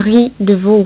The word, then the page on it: ris de veau: French